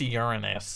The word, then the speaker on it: Uranus: It's actually pronounced Uranus.